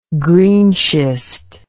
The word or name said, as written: greenschist